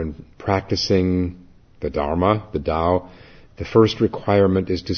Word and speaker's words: Tao: In practicing the Tao for beginners is spelled T-A-O, is pronounced as though a D, D-A-O.